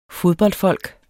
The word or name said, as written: fodboldfolk